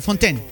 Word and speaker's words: Fontaine: Y el francés, Jos Fontaine. Es que no sé cómo se pronuncia. – Dígalo como quiera. – No, porque en inglés sería Fontaine, pero en francés es Fontaine.